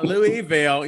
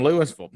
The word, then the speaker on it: Louisville: So in Kentucky, it's Louisville. It's not Louisville. You know, they'll get on the announcers when they call it. It's Louisville, Louisville.